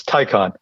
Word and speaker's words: Taycan: Is it Taycan or Taycan? What is the proper official pronunciation? – As most Porsche owners are generally very well-to-do, I've used the analogy, it's the necktie convention, so it's Taycan.